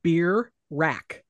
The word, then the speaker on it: Birak: So I listened to them say it a bunch of times and then I like wrote it out as syllables, like, and it just basically looks like beer rack.